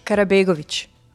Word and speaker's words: Karabegovic: Karabegovic.